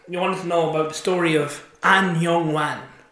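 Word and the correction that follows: Ahn Jung-hwan: A-H-N. – What are you doing? No, that's. – An Jung Hwan is how you say it.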